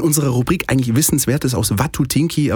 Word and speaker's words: Watutinki: Ich habe aber mittlerweile gelernt, es heißt Watutinki. Ja. ja. Die Betonung liegt auf dem, auf dem ersten Block.